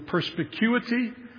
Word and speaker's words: perspicuity: Baptists believe that the scripture is a fancy word used historically, the perspicuity of scripture. Perspicuity. Five syllables, which is kind of unfortunate because what it means is that the Bible is clear.